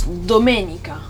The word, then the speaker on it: domenica: Then there's Sunday, that's Domenica.